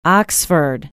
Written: oxford

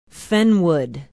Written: fenwood

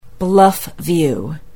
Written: bluffview

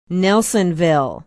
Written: nelsonville

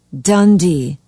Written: dundee

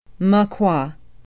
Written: Moquah

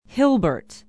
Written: hilbert

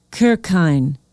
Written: kirchhayn

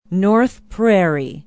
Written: north prairie

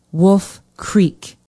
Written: wolf creek

